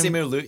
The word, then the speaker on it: Simu Liu: Yeah, Simu Liu, yeah.